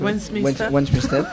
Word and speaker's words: Westminster: Westminster, ¿no? – Sí, lo he hecho. – Westminster.